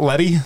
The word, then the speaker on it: Lett: So is Jordan Lett, and it's Lett, right? Not Letty? – Yep, Lett. – Okay. It's L-E-T-T, but you never know with people if they have some kind of French pronunciation or...